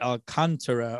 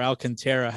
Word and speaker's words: Alcantara: Sandy Alcantara or Alcantara, how I pronounce it.